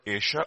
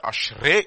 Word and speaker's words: Ashrei: And by the way, the Bible, if you look at this particular word Asher, Ashrei is another translation. I mean, it's also another pronunciation.